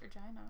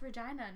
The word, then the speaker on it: Regina: And why is it pronounced Regina, not Regina? – You know, when I moved here, I was like, no, it's gotta be pronounced Regina. There's no way it could be pronounced Regina. Boy, was I wrong.